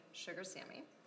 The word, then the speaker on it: Sugar Sammy: Sugar Sammy.